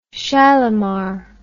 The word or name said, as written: Shalimar